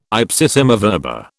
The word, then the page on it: ipsissima verba: British English